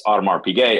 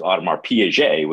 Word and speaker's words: Audemars Piguet: And if you've ever tried to pronounce Audemars Piguet as Audemars Piaget, which is about 50% of humanity, you realize how uncomfortable that can be.